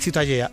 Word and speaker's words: Ciutadella: ¿cómo se pronuncia? – La catalán? Ciutadella. – Ciutadella. Bueno, pues Ciudadela también en castellano.